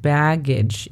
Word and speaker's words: baggage: La pronunciación fonética es B-E-A-G-E. CH. Nota que la CH no es tan dura, es un poquito más suave, entonces es baggage y no baggage.